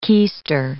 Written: keister

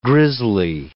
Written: grizzly

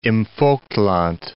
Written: Plauen im Vogtland

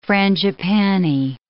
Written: frangipanni

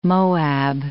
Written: Moab

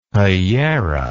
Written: pollera

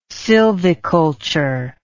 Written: sylviculture

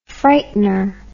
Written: frightener

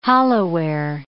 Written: hollowware

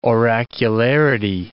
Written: oracularity

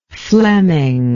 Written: flemming

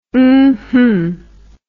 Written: umhum

